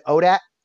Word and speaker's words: ODAAT: So ODAAT, if you're not aware, it's just pronounced for people in the community. We just pronounce it ODAT.